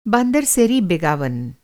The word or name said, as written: बान्दर सेरी बेगावन